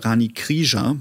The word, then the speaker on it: Rani Krija: Ich habe im Übrigen den Rani Krija gefragt, oder Rani Krija, so wird er richtig ausgesprochen, angefragt.